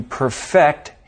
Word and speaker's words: perfect: As an example, we could say, it took the man several years to perfect his novel. Finally, last month, he decided it was perfect.